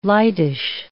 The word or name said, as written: lightish